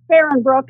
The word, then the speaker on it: Fahrenbrook: Fahrenbrook is the American pronunciation, yeah.